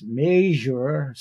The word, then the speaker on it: Meijer: I believe it's Major, spelt M-E-I-J-E-R, and H-E-B, among many others.